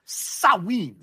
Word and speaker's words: Samhain: How did you actually pronounce this thing? – Sawin. – Sawin. – Sawin. – You went Sawin with Christopher Walken. I think it's Sawin.